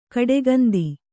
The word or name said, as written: ಕಡುಗಂದಿ